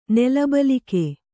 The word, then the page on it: ನೆಲಬಲಿಕೆ: kannada